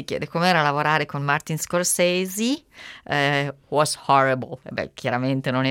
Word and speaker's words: Scorsese: Perché noi diciamo Scorsese, cioè per noi in italiano si legge così, invece in inglese naturalmente Scorsese con quella E finale che appunto si legge I, così